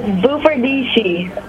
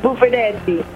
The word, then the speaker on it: Bufardici: Bufardici in America, and in Italian is Bufardici.